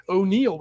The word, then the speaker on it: O'Neill: O'Neill with 2 L's, not a typo.